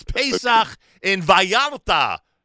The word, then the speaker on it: Pesach in Vallarta: But please, please, but I beg of you, don't change my pronunciation. – It's the right pronunciation. – Don't Americanize it on my airwaves. It's Pesach in Vallarta.